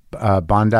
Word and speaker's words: Bondi: I went to Bondi, is that how you say it? – Yeah.